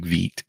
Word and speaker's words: Vite: It says it's pronounced like Vite.